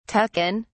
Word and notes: tuck in: British English

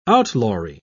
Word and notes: outlawry: British English

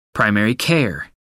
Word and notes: primary care: American English

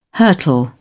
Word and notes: hurtle: British English

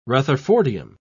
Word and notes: rutherfordium: British English

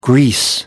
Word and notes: Greece: American English

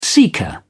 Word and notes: seeker: British English

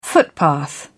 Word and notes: footpath: British English

